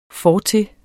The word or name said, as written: fortil